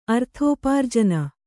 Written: ಅರ್ಥೋಪಾರ್ಜನ

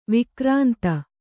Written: ವೈಕ್ರಾಂತ

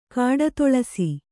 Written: ಕಾಡುತುಳಸಿ

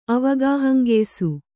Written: ಅವಗಾಹಂಗೆಯ್ಸು

